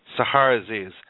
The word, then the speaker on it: Sahar Aziz: Now, today's guest is attorney Sahar Aziz. Can you please help me if I pronounce that? – Sahar Aziz. – Sahar Aziz.